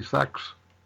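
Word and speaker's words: Sechs: He said that they call us Panzer Army Sex, the German pronunciation of it, because we only have 6 panzers left.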